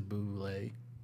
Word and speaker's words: Bublé: He changes it to Bublé.